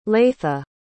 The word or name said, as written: leitha